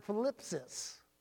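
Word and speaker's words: thlipsis: Tribulation comes through the Greek word philipsis. It's spelled T-H-L-I-P-S-I-S. T-H-L-I-P-S-I-S. Philipsis is troubles.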